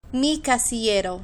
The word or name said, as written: mi casillero